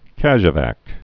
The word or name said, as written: casevac